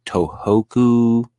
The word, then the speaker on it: Tohoku: Its effects were felt in many coastal countries, but none worse than northern Japan's Tohoku, I think.